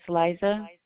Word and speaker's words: Liza: It's Liza. L-I-Z-A. Pacheco. – Could you spell that for me? – P-A-C-H-E-C-O.